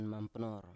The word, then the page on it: mampnor: Biak